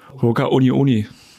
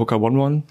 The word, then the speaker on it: Hoka One One: Hoka Uni Uni. – Wie? Hoka? – Uni Uni müsste, ist richtig. Also ich sage eigentlich nur Hoka oder Hoka One One. Aber ich glaube, richtig wäre Hoka Uni Uni.